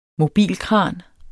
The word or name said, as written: mobilkran